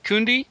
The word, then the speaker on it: Cundey: Cundey.